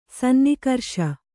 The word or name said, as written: ಸನ್ನಿಕರ್ಷ